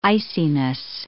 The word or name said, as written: iciness